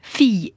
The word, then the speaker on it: fille: Donc c'est vrai, fille et ville s'écrivent presque pareil, et se prononce différemment. Famille, gorille, anguille, aiguille, camomille, en fait, presque tous les mots qui se terminent en I2LE se prononcent comme fille. Il n'y a que trois mots, en fait, qui fassent exception à la règle.